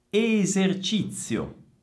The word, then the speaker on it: esercizio: Esercizio. Esercizio. Occhio alla C e alla Z. È la stessa Z di pizza. Zi, zi, zio, zio. Esercizio.